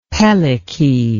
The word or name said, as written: pelike